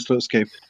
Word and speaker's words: Slutsky: What was the crack with Leonard, was it Slutsky? Is that the correct pronunciation? – Yeah, Leonard Slutsky.